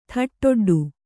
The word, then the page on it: ಥಟ್ಟೊಡ್ಡು: thaṭṭoḍḍu